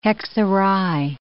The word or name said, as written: hexerei